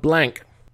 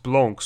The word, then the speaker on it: Blanc: drinking Sauvignon Blanc. – Blanc. – What? – It's pronounced Blanc, Sauvignon Blanc.